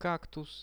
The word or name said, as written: kaktus